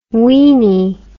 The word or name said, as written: weinie